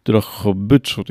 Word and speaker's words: Drohobycz: E, poseł niedawno był w, w Drohobyczu, jeżeli się dobrze, e, do, dobrze wymawiam i jeżeli dobrze zapamiętałem nazwę, tak? – Tak, Drohobycz, miasto na Szulca.